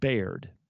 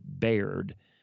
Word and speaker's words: Bayard: He's got a church that is an hour from here, that is called Baird. Now, if you look it up, you would look up Bayard, B-A-Y-A-R-D, but they pronounce it Baird, New Mexico.